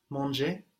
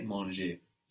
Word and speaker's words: mangé: So it sounds the same as the two verb, manger, to eat with the ER ending, and then manger with an E with a written accent, meaning eaten, but sounding exactly like to eat, manger. So, I have eaten. – J'ai mangé. – J'ai mangé. So this manger is spelled M-A-N-G-E, and that E has the accent on the top, the one that goes down from right to left.